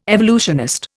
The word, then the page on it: evolutionist: Indian